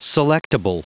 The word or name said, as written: selectable